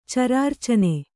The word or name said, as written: ಚರಾರ್ಚನೆ